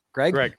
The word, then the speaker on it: Greig: So how do we say this kid's name? Gregg. So Ridley Gregg.